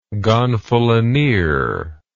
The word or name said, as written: gonfalonier